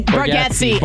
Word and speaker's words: Bargatze: Is it pronounced Nate Bargatze? – Bargatze.